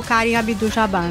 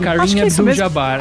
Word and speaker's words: Kareem Abdul-Jabbar: E é o Magic Johnson e o Kareem Abdul-Jabbar, né? – Exato. – É assim que fala, gente, eu falei errado. – Kareem Abdul-Jabbar,